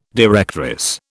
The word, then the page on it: directress: British English